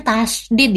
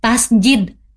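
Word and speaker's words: tasydid: Dibacanya tasdid ya, bukan tasjid ya.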